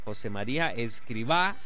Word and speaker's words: Escrivá: Tiene acento en la A, José María Escrivá.